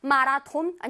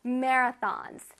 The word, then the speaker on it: marathons: 마라톤 아니고 marathons. Marathons, okay? 조금 어렵지, 발음이.